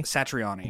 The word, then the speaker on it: Satriani: How do you pronounce his last name? Satriani? – Satriani.